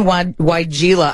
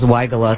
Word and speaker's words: weigela: Now, you mentioned weigela. I always thought it was weigela, so it's so nice to know how to say it finally. That, Sonic Bloom weigela, is part of a Proven Winners Best of Garden giveaway.